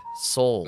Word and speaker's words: Sowell: Like that Thomas Sowell guy? – Yes, yeah. Thomas Soul, Mike. Soul. – Is that how you say it? – Oh, yeah, come on.